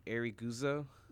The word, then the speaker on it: Ariguzo: Ariguzo. – How do you spell that? – O.B. Ariguzo. A-R-I-G-U-Z-O.